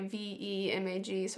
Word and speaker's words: VEMAG: So I Googled co-packers, and then I called every co-packer and asked if they had a VMAG, which I was pronouncing it wrong because I thought it was like a VMAG, and it's like a VEMAG. So I was calling it a VMAG.